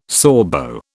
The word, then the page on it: Sorbo: British English